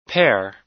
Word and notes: pair: American English

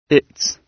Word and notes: its: British English

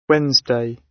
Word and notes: wednesday: British English

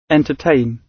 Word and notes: entertain: British English

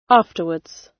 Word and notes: afterwards: British English

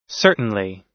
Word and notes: certainly: American English

